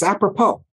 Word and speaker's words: apropos: It has a silent S at the end of apropos, by the way. Okay. – People think that word is funny and don't know how to spell it. It's apropos.